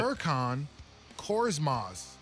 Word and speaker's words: Furkan Korkmaz: But Furkan Korkmaz.